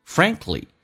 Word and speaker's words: Frankly: Frankly 首先我们来看一下这个单词指的是坦白的说，相当于 To be frank with you To be frank with you 坦白的说，首先第一个 k 是可以不用发音，